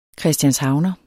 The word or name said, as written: CHRISTIANSHAVNER